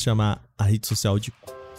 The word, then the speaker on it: Koo: A rede social se escreve sim K-O-O. Só que a pronúncia oficial é essa mesmo, cu.